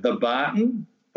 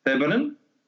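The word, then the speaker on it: Thebarton: Often interstate people call it the Barton, but it's actually pronounced Theberton.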